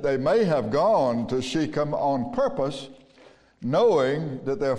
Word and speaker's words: Shechem: His brethren went to feed their father's flock in Shechem. If you want to pronounce it Shechem, that's okay.